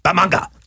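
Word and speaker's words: Bat-Manga: Well, but the really, really cool thing was the book I eventually did on Batman in Japan, which, by the way, the correct pronunciation is Bamanga. Bamanga. Right. Bamanga.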